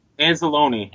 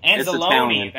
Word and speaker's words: Anzalone: Anzalone. – Anzalone.